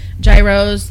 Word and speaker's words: gyro: They've had gyros, they've had... – Gyros? – I know, but everyone makes fun of me when I say gyro. – That's how you say it.